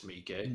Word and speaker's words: Miike: Is that really how you pronounce Mike's name? – No, it's Miike.